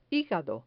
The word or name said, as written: higado